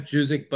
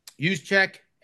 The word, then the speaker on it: Juszczyk: Not Juzik, but Juszczyk, the fullback for the 49ers.